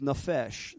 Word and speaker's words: nephesh: Now the word mind there is nephesh. That's N-E-P-H-E-S-H. Nephesh. Okay? Spelling is always different than the pronunciation.